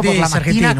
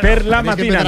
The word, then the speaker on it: per la mattina: Es buongiorno por la mattina, que decís siempre aquí. – Tienes que empezar a decirlo. Per la mattina, no por la mattina.